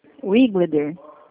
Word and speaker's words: Wigleder: Por gentileza, nesse telefone eu consigo falar com o senhor Wigleder? – Wiglider. – Wigleder? É Isso. – assim que se pronuncia? – Exato.